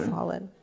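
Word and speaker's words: Falun: He ends up getting a job at, how did I say it? Fallon Hospital? Fallon. Like someone fell down earlier. They've fallen.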